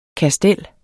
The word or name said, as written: kastel